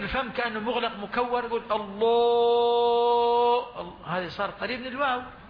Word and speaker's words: الله: في بعض الناس مثلا إذا لم يغلظ إذا لم يرقق الهمزة سوف يقول الله الله ففخم الهمزة معها، هذا خطأ. الصواب الله أعلم، الله الله. لاحظوا هنا عندما نطقت لفظ الجلالة بهذه الكيفية رققت الهمزة والهاء، وتغليظ اللام هنا يعني في وبضدها تتميز الأشياء.